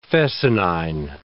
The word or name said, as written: fescennine